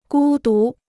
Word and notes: 孤独: gū dú

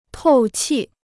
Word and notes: 透气: tòu qì

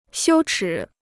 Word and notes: 羞耻: xiū chǐ

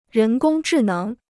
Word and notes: 人工智能: rén gōng zhì néng

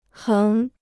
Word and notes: 横: héng

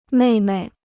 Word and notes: 妹妹: mèi mei

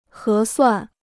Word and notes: 合算: hé suàn

